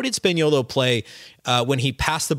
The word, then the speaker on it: Spagnuolo: And then really interesting thoughts from Rafael Barlow on Matteo Spagnuolo, And I pronounced his last name wrong last week, so I apologize for that. Spaniolo is how you say his last name.